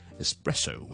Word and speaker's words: espresso: 有唔少人包括係講英文嘅人都會將佢咧係錯誤讀成咧 espresso 嘅，咁其實就唔需要 es 咧，我就咁 s s s 或者 is is is 就得，咁我哋就唔需要咧，好似 express e x 嗰個 x 咧就讀成 x 加 k 喺度嘅，OK 只需要 espresso 或者 espresso。